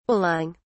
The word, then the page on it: balling: British English